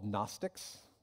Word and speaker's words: Gnostics: Gnostics, with a silent G,